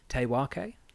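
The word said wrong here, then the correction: Te Wheke: Te Whaki. – Sorry. I'm going off of phonetic reading with my silly colonial reading. So it's Te Whaki.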